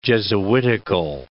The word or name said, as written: jesuitical